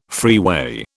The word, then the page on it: freeway: British English